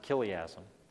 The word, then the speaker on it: chiliasm: It was known back then as chiliasm. Looks like chiliasm, but you pronounce it chiliasm.